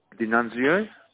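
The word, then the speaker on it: Di Nunzio: D'Annunzio.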